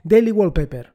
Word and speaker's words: Daily Wallpaper: Respecto al tema de aplicaciones, bueno, la primera es, y ya lo comenté en el episodio del podcast del lunes, es Daily Well... well uh, madre mía, cómo estoy con la pronunciación de inglés. Daily Wallpaper. ¿Qué pasa con Daily Wallpaper?